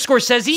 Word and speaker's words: Scorsese: Lorraine Bracco It's Scorsese, motherfucker.